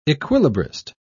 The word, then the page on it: equilibrist: British English